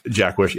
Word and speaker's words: Jaquish: The other thing that John, and I think you pronounce his name, Jaquish. I could be wrong about that. – I think it's Jack. – Jack Wish, yeah.